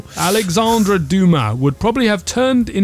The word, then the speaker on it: Dumas: It is spelled D-U-M-A-S, Dumas, but I think you don't pronounce the S because it's French.